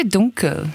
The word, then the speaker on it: DNKL: The second song is, I don't actually know if you pronounce it DNKL or Dunkel. – I'd say Dunkel.